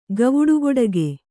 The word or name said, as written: ಗವುಡುಗೊಡಗೆ